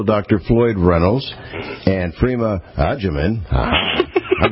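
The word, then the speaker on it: Agyeman: Edgeman. Phonetically figure that out like we would use in Italian, agita.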